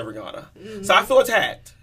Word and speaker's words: Rihanna: You get to see Rihanna's thought process. Yes, yep. Now I'm saying Rihanna because that's how you say it. And now that I know it, I can't unknow it.